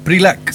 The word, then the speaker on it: PRILAC: Bueno, así también lo hemos visto por redes sociales la oficina del PRLAC. ¿Así se pronuncia? – PRILAC. – Sí, el sí. PRILAC, ahí sí.